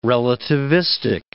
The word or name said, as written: relativistic